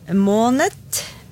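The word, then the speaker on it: måned: Og så kan det også uttales månet med T, og der kommer vi inn på noe som du sikkert kjenner til fra tysk, sant? – Ja, månet.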